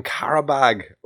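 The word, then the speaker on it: Qarabağ: You've got Karabag right, but it's Azeri, not Azerbaijani.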